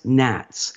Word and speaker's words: gnats: So G-N-A-T-S, fungus gnats. – G-A-U-T-S? – G-N-A-T-S. – Okay. – So it's gnats or gnat. Well, the G is silent.